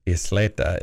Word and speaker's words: Isleta: It's, and I may be mispronouncing this, it is Isleta. Isleta. – I believe that's Isleta.